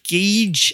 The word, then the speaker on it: gauge: So the word gauge is next. Gauge. G-A-U-G-E, not gauge and not gauge. Gauge.